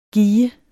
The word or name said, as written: gige